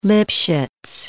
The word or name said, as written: Lipchitz